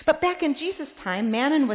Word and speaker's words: mammon: Older translations of this text, and maybe this is a familiar phrase for some of you, you cannot serve both God, and I know I'm going to be corrected if I say this word wrong, mammon. Did I say it right, Jane? Okay, good.